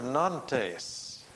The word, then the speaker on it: Nantes: Finally, the Edict of Nantes, N-A-N-T-E-S. How's my French? I don't think that's the way it should be pronounced in French. Can somebody tell me how it should be? – One syllable. – Nantes? – No, non. All right. Thank you. Thank you. N-A-N-T-E-S.